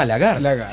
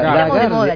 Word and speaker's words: Lagarde: Pero pronuncien la E, por favor, porque igual francés es Lagarde.